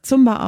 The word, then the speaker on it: Zumba: Manche sprechen das Zumba aus. Landläufig oder weitläufig wird es allerdings Zumba ausgesprochen. Ja, wie bin ich dazu gekommen?